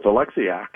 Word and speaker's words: Oleksiak: I believe it's Oleksiak. Does that sound right? And I only know that. I know very little about the man, really. I mean, he's a number 7 defenseman, whatever. But his sister was a fairly famous Olympian in Canada, Penny Oleksiak. So assuming her last name is pronounced the same as her brother's, and I'm going to guess that it is, we're going to go with that.